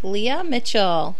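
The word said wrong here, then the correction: Lea Michele: Michelle. – Sorry. Leah Michelle. I've been saying it wrong this whole time. I've always thought it was Mitchell.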